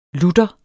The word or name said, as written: lutter